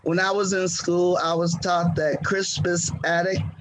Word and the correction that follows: Crispus Attucks: Crispus Attucks, yeah.